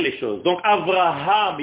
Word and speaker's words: Avraham: Il faut le dire et il faut prononcer, ce qui est difficile pour les francophones, le hé. C'est pas Avraham, comme s'il y avait quatre A.